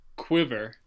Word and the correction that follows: Quibi: Quibi. – Quibi. – Q-U-I-B-I.